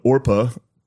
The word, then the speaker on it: Orpah: I feel like Oprah's Her first name was spelled Orpa, O-R-P-A-H, on her birth certificate. But people mispronounced it regularly, and so Oprah stuck.